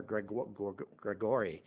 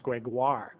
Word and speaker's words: Gregoire: I used to call her Gregory, but it's pronounced Gregoire. Gregoire. I didn't have the right pronunciation.